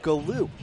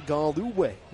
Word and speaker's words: Galue: Galloway spells his name G-A-L-U-E, which on first glance gives you Galoo. In fact, though, it's Galloway. His first name is spelled O-V-E-R, like over, over.